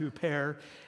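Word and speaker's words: huper (ὑπέρ): In Greek, it's pronounced huper, and that has kind of morphed over the centuries to our word super.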